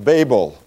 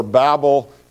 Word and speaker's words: Babel: So we're going to be focusing on the story of the Tower of Babel, or Babel, if you prefer to pronounce it according to the word that has come from it.